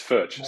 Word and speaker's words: Foertsch: Did I pronounce it right? – You've done a lot better than others have. It's Forsch, so well done.